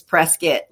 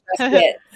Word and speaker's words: Prescott: Oh, and that I wanted to tell you, if you're walking around doing the shops and you want to, it's spelled Prescott, but the locals, it's Prescott, like business. – Whoops. – So it's Prescott.